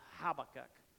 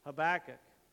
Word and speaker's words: Habakkuk: Some people pronounce this Habakkuk, but that's wrong. That's okay. It just depends on what syllable you want to put the emphasis, right? Changes a Habakkuk.